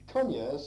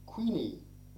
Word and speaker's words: Cunya: The name Cunya seems to be changing to Queenie, but the rich pronunciation is well worth retaining in Cunya as in Killia.